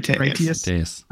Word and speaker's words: Critias: How do you say it again? – Critias.